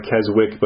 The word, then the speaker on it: Keswick: I know it looks like Keswick, but it's pronounced Keswick.